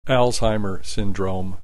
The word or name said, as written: Alzheimer syndrome